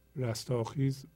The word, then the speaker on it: رستاخیز: این تلفظشه رستاخیز